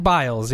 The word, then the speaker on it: Biles: That's why I didn't want to say the last name, because I'm like, there's no way it can't be Biles. It's spelled like Biles, yes.